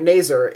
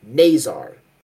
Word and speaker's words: Nazar: And we can officially confirm, last name pronunciation is Nazar. So the first syllable I've always heard like Nazar or Naser. It's kind of weird because like it's one syllable pronounced one way and then the other syllable pronounced the other way. It's Nazar. So I can confirm that's how it is,